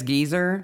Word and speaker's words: geyser: Never mind that Brits can pronounce geyser as geezer. So like Old Faithful is a geezer.